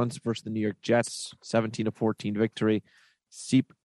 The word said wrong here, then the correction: Sipe: goes, am I saying that wrong now? – Oh, yeah, it's it's Sipe. – It's Sipe.